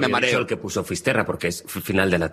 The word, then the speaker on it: Fisterra: Entonces, llegó a Finisterre. ¿Se dice así? – Fisterra.